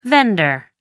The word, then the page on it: vender: American English